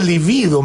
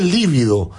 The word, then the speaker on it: libido: Y la libido, se dice libido, me acaba de corregir un auditor, que no se dice libido, ¿ah? El acento en la segunda sílaba. – No, yo tenía entendido. – No, en la primera. Libido.